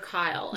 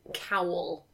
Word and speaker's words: Kyle: Name is for sure Kyle, and everyone else refers to him as Kyle, but Shatner apparently pronounces it as Cowl.